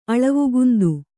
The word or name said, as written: ಅಳವುಗುಂದು